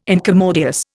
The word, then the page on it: incommodious: Indian